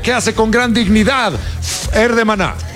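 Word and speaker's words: Fher: Fer con H, ¿no? – Fer. Así se pronuncia, Fer.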